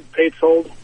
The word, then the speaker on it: Paysold: Yeah, no, it's a little difficult, but it turns out just like it's spelled, Paysold.